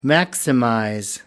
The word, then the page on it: maximize: American English